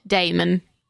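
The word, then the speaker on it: daemon: And it's spelled funky. – Damon. You're Damon.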